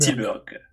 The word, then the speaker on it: Tilburg: Et donc, le festival dont on parle, c'est le Roadburn 2022, donc qui se tenait aux Pays-Bas à Tilburg. – Tilburg, yes.